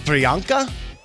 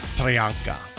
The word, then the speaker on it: Priyanka: Is it Priyanka? – That sounds good. – Is that how you would say it? – Mm-hmm. – Hello, Priyanka. – If you ever meet her, make sure you pronounce it like that.